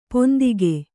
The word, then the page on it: ಪಿಂತೆಗೆ: Kannada